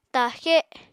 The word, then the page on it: ta7xe7: apatani